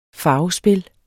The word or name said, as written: farvespil